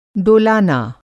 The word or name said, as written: डोलाना